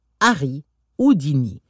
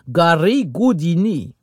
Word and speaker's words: Harry Houdini: Harry Houdini. Ou plutôt, comme on le prononce là-bas, Gary Houdini.